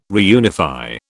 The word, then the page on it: reunify: British English